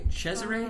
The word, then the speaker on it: Cesare: Cesare, mm-hmm.